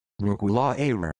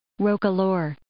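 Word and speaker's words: roquelaure: So, and for comparison, here is the Merriam-Webster pronunciation of it as well, which matches Emma. – Roka lore.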